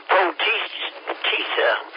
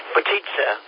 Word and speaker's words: potica: Well, it's Potizza. Potizza. P-O-T-I-C-A, Bill. It's not spelled like pizza.